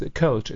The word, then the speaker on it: Kölsch: It's Kölsch. It's called. – Kölsch? – Kölsch. – Kölsch. – Kölsch. Oh, Kölsch. – Kölsch. – How do you spell it? – Kölsch. – K. O-E-L-S-C-H. – Oh, Kölsch. – Okay, cool. – It's the O with the dots on the, okay. – on the top.